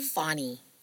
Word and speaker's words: Fonny: Whereas Phony is Is that how you pronounce his name? – Fonny. – Fonny. – Yeah.